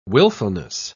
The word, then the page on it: wilfulness: British English